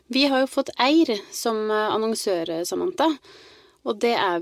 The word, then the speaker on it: Eyr: Og en ting jeg ble så usikker på var hvordan det her egentlig staves, fordi det uttales jo Eir, men det staves E-Y-R.